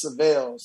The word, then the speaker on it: Syvelle: They have um, they have this uh, a car called the Seville. – Yeah. – And, and my is Syvelle, so you know, uh, a lot of times, you know, the pronunciation of my name was always uh, mixed up.